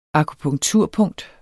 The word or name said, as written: akupunkturpunkt